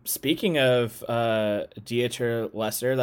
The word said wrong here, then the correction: Dieter Laser: that's another, or Laser, Dieter Laser. Am I pronouncing that correctly? – Dieter Laser. Yeah.